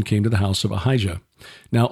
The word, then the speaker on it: Ahijah: Behold, well, and then Ahijah, but it's probably Ahiyah.